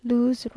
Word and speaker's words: luz: Everything is put into verse, sometimes doggerel, like the above, in which luz, rhyming with Jesus, shows that the Z is pronounced here like an S, occasionally a little better, but always in rhyme.